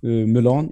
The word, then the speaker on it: Mulan: Eller Mulan, som en del säger. Nu är vi där igen. Hur ska det egentligen uttalas? Um. den, ska, den ska uttalas Mulan.